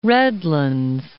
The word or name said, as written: Redlands